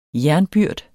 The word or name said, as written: JERNBYRD